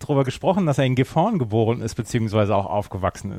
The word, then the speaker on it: Gifhorn: Dürfte dann sogar Gifhorn sein, mit Betonung auf der ersten Silbe. – Oh, Entschuldigung, Gifhorn, ja.